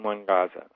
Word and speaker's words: Nwagaza: I think it's W-A-G-A, W-A-G-A-Z-A. – N-W-A-G-A-Z-A? – In one Gaza. Mm-hmm. That sounds... – No, you don't pronounce the N. You just pronounce one Gaza. – One Gaza. Mm-hmm. – Yeah, the N is silent.